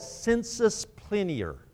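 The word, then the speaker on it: sensus plenior: They call it sensus plinior. That's a Latin term. Sensus plinior. Let me spell that for you. S-E-N-S-U-S, sensus, and then P-L-E-N-I-O-R, plinior, sensus plinior, which simply means fuller sense.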